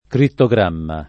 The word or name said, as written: crittogramma